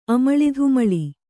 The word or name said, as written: ಅಮಳಿಧುಮಳಿ